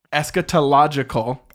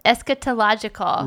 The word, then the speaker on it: Eschatological: Wait, how do you say it? – Eschatological. – Eschatological.